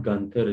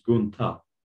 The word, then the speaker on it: Gunther: It's not Gunther, it's Guntha.